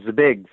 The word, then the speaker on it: Zbig: Zbigniew, but they call him Zbig for short, like Z-B-I-G, Z like zoo, B-I-G.